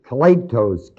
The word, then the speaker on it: kletos: This word here in Romans 1:1, the word called, is the word, Greek word, kletos, K-L-E-T-O-S, or kletos. Pronounce it whichever way you like, I'll spell it for you. K-L-E-T-O-S.